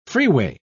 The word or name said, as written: freeway